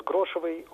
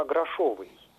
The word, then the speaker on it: грошовый: А мой вопрос про другое слово из этой песни, там поется про презревших грошевой уют, и некоторые исполнители произносят грошевый уют, вот при том, что литературная норма грошовый. А остальные варианты, грошевый и грошевой, они, в общем-то, не допускаются даже в качестве разговорных.